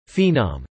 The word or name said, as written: phenom